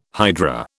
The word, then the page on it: hydra: British English